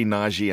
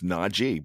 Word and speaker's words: Najee: and it's Najee Harris. Remember, he yelled at me, it's Najee. So if you say Najee, I'm going to have to correct you. It's Najee because he's serious about that that's one thing that that he wants.